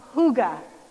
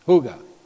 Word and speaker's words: hygge: The Danes say that their happiness is due to their understanding of hygge. – Hygge. – Hygge. – Spell that. – H-Y-G-G-E.